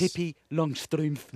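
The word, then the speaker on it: Pippi Langstrumpf: I think that's how you say it in the country where it's from, which is somewhere in Europe like Dutchland. – Ah, yes. – Pippi Longstrumpf.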